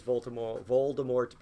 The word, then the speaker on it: Voldemort: With Harry Potter, how do we know that, how do you say it? I always say it wrong. Voldemort. There's a T. Whatever you're saying, that guy. Sorry. Thank you. DT. Voldemort.